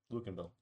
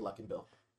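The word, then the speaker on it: Luckinbill: Lauren Luckenbill. – Yeah. – No, Luckenbill. – Luckenbill. – Luckenbill. – Luckenbill. – Yes, thank you. I'm glad you agree. His name is... It's actually Luckenbill.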